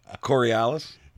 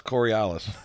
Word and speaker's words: Coriolis: Corlanilis. There's no N in there. – There it is. – Still can't even say it right. Coriolis.